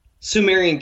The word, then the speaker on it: Sumerian: Sumerian King List, yeah.